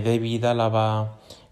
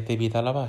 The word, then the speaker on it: Alaba: Una de las principales novedades fue que David Álava, eh, y lo pronuncio así porque ayer viendo el partido con la retransmisión, con la narración de David Álvarez en Ramari Televisión, comentaba que se lo preguntaron cuando iba, fin, cuando se presentó Em, Álava em, como jugador del Madrid se lo preguntaron en el coche camino de Valdebebas y él dijo que se pronunciaba su nombre David Álava, así que así lo vamos a decir.